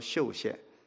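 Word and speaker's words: 宿县: 到最后的一点，现在可以讲，就到了一个你们可能没有听过的地方，那个是在安徽省的北边，有一个小地方叫做秀县，就是住宿的那个宿字，当地人读成秀字哈。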